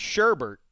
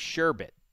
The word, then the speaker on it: sherbet: It is not sherbet like most people say. – Yes. – It is sherbet. There is no second R, even though most people think there is.